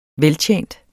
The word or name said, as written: VELTJENT